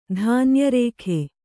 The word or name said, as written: ಧಾನ್ಯರೇಖೆ